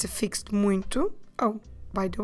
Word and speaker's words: muito: M-U-I-T-O. Muito. You might have noticed that when we pronounce this word, there's a nasal sound, muy, but when we actually spell it, there's nothing there to tell us about that nasal sound.